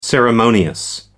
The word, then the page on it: ceremonious: American English